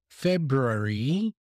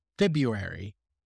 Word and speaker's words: February: In my case, I would never pronounce the R after the B. So I would not say February, because that's a very difficult consonant cluster. So many, many native speakers pronounce February.